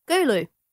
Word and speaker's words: Gulu: You have chosen a North Ugandan city, and I'm going to pronounce this wrong as well, Gulu? – Gulu.